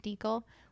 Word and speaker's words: Dekle: It's at Haley Deekle. Last name is spelled D-E-K-L-E.